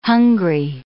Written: hungry

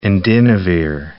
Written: indinavir

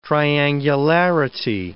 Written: triangularity

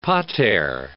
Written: pater

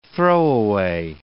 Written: throwaway

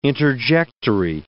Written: interjectory

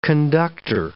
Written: conductor

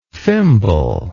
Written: fimble

